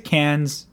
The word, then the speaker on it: Cairns: So you get to Cairns.